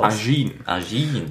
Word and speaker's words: Ajin: Ajin.